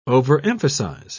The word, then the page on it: overemphasize: British English